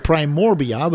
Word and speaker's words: primordia: The primordia, which are these sprouting bodies. How do you say it? – Primord as in dog, IA.